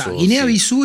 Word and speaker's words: Guinea-Bissau: Guinea-Bissau es la correcta pronunciación de esto.